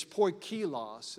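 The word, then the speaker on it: poikilos (ποικίλος): It's poikilos. It almost sounds like a Hawaiian food to me.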